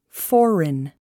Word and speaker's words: foreign: The letter G is often not pronounced when followed by an N. Foreign, sign.